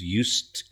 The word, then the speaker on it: used: So that word used, yeah, it's a past tense of to use, used, U-S-E-D, but you've got a T sound at the end.